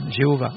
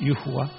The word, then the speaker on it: Yehovah: Les gens en français, certains prononcent Jéhovah, mais ce n'est pas Jéhovah, c'est Yehovah.